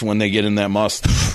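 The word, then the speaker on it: musth: They blame the elephant's aggressive behavior on the fact that he was in a heightened state of sexual tension known as must. – What? – Yeah. Must. You don't pronounce the H? – I don't think so. Must. Like must. Must.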